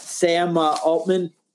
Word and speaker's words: Sam Altman: But I think a lot of this, I have to point out that Sam Altman.